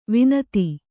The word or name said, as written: ವನಧಿ